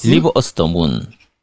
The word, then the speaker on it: Deliver Us the Moon: Deliver Earth the Moon. 아, Earth 하면 안 되는구나. Us the Moon. Deliver Us the Moon.